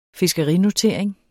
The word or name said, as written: fiskerinotering